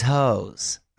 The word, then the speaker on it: Heaux: Well, because she's got a song called These Hoes. But isn't she 14? – Something like that. It's spelled H-E-A-U-X, okay? Not to be confused with the garden tool.